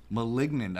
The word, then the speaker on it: Malignant: Maligant? Is that how you pronounce it? – Why are you asking me? – I didn't go to school. JK, I did. – Maligant. No, it's called Maligant. Pronunciation. Malignant. I think that's what it is. Yeah. – Oh, malignant.